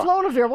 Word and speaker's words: Flodavieur: F-L-O-D-A-V-I-E-U-R. – Flodavir. – Yeah, Flodavir.